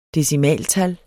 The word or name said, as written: decimaltal